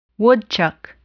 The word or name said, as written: woodchuck